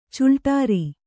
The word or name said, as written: ಚಿಲ್ಟೇರಿ